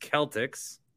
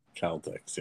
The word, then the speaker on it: Celtics: Matt says it's pronounced Celtics. – Celtics, yes.